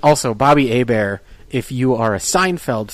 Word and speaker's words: Hebert: it's spelt Herbert, it's spelt Bobby Herbert, but it's pronounced Hebert, you know, because it's French.